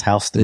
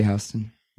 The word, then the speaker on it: Houston: It's pronounced Houston. – They say Houston.